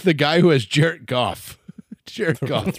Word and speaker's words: Goff: Used to call Jared Gorf. – Gorf. – That's true. You did. – He's amazing. – Very rude to mispronounce anyone's name.